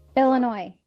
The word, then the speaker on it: Illinois: Illinois.